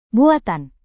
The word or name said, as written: buatan